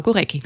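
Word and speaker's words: Górecki: Zurück nochmal zu Goreckis Sinfonie oder vielen Dank natürlich auch für die Anrufe, die hier schon ankamen mit dem Hinweis, es heißt Gorecki. Also Claudia und ich haben uns vorher dann auch ein bisschen erkundigt, so ist es nicht, die internationale Aussprache ist. – Genau, Gorecki.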